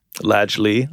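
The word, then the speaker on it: Ladj Ly: Lajli.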